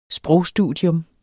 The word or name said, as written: sprogstudier